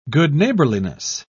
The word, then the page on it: good-neighbourliness: British English